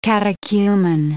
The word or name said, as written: catechumen